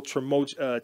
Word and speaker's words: tumultuous: And Aaron Rodgers went through a whole tumultuous, how do I say that? – Tumultuous. – There we go. – Tumultuous. – Yeah. There it is.